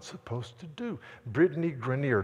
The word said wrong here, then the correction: Griner: did I say her name right? The Baylor basketball player, somebody help me out here. Is it Grenier? Griner, whatever. Tall, good, good basketball.